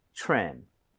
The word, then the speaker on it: trim: 그래서 다듬음, 다듬다라는 거둘다될 수가 있는데 이거 트림이 아니죠. Right? TRE 붙어 있어요. TRE 붙어 있고 그다음에 모음으로 연결이 되면 크리스마스 트리처럼 치읓 비슷해집니다. – 그쵸. – 그래서 이 모양을 모으고 발음해 주세요. 가보겠습니다. Trim, trim.